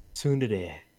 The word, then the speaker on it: tsundere: How, how do you pronounce it? – Tsundere. – Tsundere.